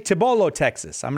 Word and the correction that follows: Cibolo: It was close. It's Cibolo.